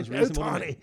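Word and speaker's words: Ohtani: Otani, I can't pronounce his first name, Howard. I'm going to just go with Otani here. – Otani, is that all right? – Otani.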